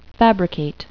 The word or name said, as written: fabricate